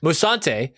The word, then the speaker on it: Musante: Musante as Sam Dalmas.